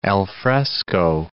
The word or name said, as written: alfresco